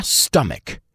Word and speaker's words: stomach: Mucha gente conoce la palabra stomach, que significa estómago, pero pocos la pronuncian bien. Se pronuncia stomach.